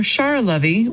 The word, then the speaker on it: Sharlovy: This is from Charlovy, which is spelled S-H-A-R-L-O-V-Y, and it is $14 at Amazon.